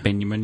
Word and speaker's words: Benjamin: That J is not a soft J, folks. It's more a German Y, Benjamin, yep.